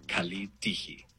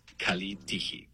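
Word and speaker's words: kalitigi (καλή τύχη): En griego moderno se dice kalitigi. Kalitigi. Pronunciación aproximada. Kalitigi.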